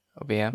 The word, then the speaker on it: Obeah: I don't know much about Obeah. Am I saying that correctly? Obeah? – Yeah, Okay.